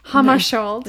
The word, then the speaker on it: Hammarskjöld: Hammarskjöld. – Yeah. Yeah. – That's how you say it. Mm-hmm. It's spelled not like that.